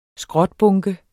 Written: skrotbunke

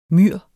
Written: myr

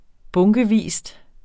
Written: bunkevis

